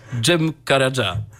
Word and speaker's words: Cem Karaca: Tak, a zatem posłuchamy teraz utworu Ceviza Ce, a wykona go dla was Cem Karaca.